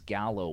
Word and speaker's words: Gallo: The voice of the Moose Jaw Warriors and so much more, James Gallo, welcome back to the show, my friend.